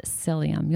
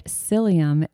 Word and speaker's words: psyllium: And then ground psyllium. You'll see this. You can buy it in powdered form, blended into smoothies. You can take it in capsule form. But psyllium is, and it's spelled P-S-Y-L-L-I-U-M.